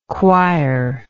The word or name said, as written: choirlike